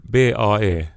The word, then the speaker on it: bae: Jag tror att för mig hände det nog redan, eh, jag tror det var eh, no, kanske vid Bay, det här BAE, som jag, eller uttalas den så? Jag vet inte så. – Alltså, ja, Bae.